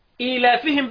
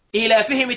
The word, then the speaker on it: إيلافهم: إيلافهم التانية الياء مش مرسومة بس كله بينطقها.